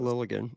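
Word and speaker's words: Liljegren: Lilligren has extra letters in it. – That's so good. You just don't pronounce those. Lilligren.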